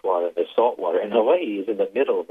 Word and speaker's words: Hawaii: So the context of Hawaii is, you know, as you, and I thank you for pronouncing it the way you've done, because, the breath that I just talked about.